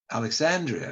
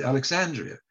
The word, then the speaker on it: Alexandria: I used the word Alexandria, and I said Alexandria. And British don't pronounce it that way, Alexandria.